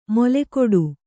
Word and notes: ಮೊಲೆಗೊಡು: molegoḍu

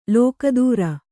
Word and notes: ಲೋಕೋತ್ತರ: lōkōttara

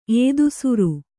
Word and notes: ಏದುಸಿರು: Kannada

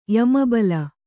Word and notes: ಯಮಬಲ: Kannada

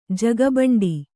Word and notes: ಜಗಬಂಡಿ: jaga baṇḍi

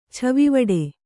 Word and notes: ಛವಿವಡೆ: kannada

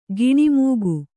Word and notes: ಗುಣಮುಖ: kannada

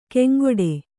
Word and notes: ಕೆಂಗೊಡೆ: keŋgoḍe

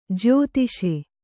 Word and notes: ಜ್ಯೋತಿಸು: jyōtisu

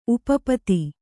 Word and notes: ಉಪಪದ: upa pada

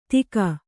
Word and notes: ತೀಗ: kannada

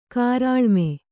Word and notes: ಕಾರಾಳುಮೆ: kannada

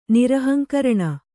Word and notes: ನಿರಹಂಕರಣ: Kannada

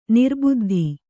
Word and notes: ನಿರ್ಬದ್ಧ: nirbaddha

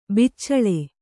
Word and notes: ಬೆಚ್ಚಳ: beccaḷa

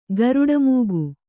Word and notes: ಗರುಡಮೂಗು: garuḍa mūgu; Kannada

